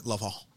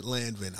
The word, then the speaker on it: Lanvin: Lanvin. – I don't know how you, you know, don't pronounce it Landvin, obviously.